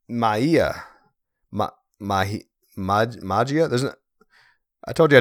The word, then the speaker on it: Magia: Do you pronounce the G's? I don't think you do. I think it's like an H. Magia, which is Spanish for magic, okay?